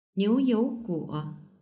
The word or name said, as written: niuyouguo